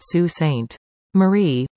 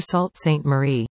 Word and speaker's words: Sault Ste. Marie: Sioux folks know that it is pronounced Sault Ste. Marie, but it never fails that wherever we go, we hear Sault Ste. Marie.